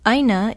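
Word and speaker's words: Aina (ʻāina): Aina is spelled with an okina, A-I-N-A. Kakahiaka is spelled K-A-K-A-H-I-A-K-A.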